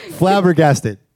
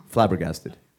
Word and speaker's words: flabbergasted: ¿Cómo se pronuncia eso? – He just said it. – Flabbergasted. – ¿Flaga qué? – Make our job easier.